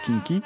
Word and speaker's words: quinqui: Ça s'écrit kinky, hein, Q-U-I-N-Q-U-I, mais ça se prononce kinky, qui est mise à l'honneur, ce cinéma, ce type de cinéma, dans un superbe coffret sorti chez Artus Film.